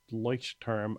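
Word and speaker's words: Leuchtturm: So now I've always used the Leuch term, I think is how you pronounce it. Sorry for... – No, I think you're right.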